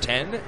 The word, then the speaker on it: Then: Juan Ten pronounced Ten, spelled T-H-E-N.